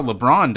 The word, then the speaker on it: LeBron: B, that's how LeBron does it.